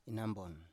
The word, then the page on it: inanbon: Biak